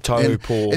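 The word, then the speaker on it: Taupō: Instead of Taupo, guys, it's Taupo. – Topo.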